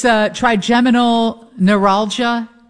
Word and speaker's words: trigeminal neuralgia: And it's trigeminal neuralgia.